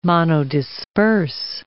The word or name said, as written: monodisperse